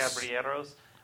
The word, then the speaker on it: Caballeros: It's this boat ride, and you see like, I can't remember pronounce it, the Three Caballeros? – Caballeros? – Caballeros.